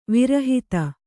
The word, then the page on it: ವಿರಹಿತ: virahita